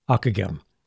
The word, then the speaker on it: Ockeghem: His name, by the way, is spelled O-C-K-E-G-H-E-M, Akagem.